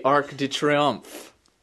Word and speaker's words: Arc de Triomphe: And the Arc de Triomphe. How do you say that? Is that right? – Sounds good to me.